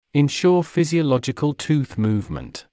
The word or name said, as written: ensure physiological tooth movement